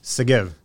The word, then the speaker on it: Segev: Segev.